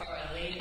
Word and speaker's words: Farlee: My last name is spelled F-A-R-L-E-E, not E-Y, so. Francis, Jeff, Farley,